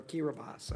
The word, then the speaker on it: Kiribati: Next, we have two treaties which, if ratified, would establish maritime boundaries between the Republic of Kiribati, am I saying that right? Kiribati? Or Kiribati, all right, thank you.